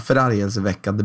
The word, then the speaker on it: förargelseväckande: Ja, förargelseväckande beteende. – Ja, förargeväckel... Vad fan, det är svårt. – Förargelseväckande. – Där ja.